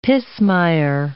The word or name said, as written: pismire